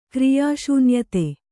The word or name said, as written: ಕ್ರಿಯಾಶೂನ್ಯತೆ